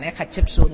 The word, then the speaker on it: Hatshepsut: O sigui, una cosa és la dona de la reialesa, que tenim alguns noms com aquests que els has pronunciat perfectament, Hatshepsut, Nefertiti, Nefertari, etc, etc.